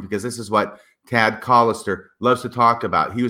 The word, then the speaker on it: Callister: And actually, it's Callister. – Callister. Look at that. – Yeah. So don't be mispronouncing his name.